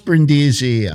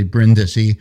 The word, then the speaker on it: Brindisi: Or was it Yeah, it was pronounced Brindisi. It's actually Brindisi.